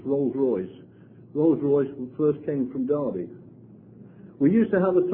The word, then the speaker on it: Derby: Now, for those of you who don't know where Derby is, you probably even think it's pronounced Derby. But it is actually Derby.